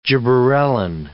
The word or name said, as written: gibberellin